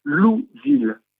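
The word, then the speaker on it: Louisville: le nom de cette ville se prononce Louville.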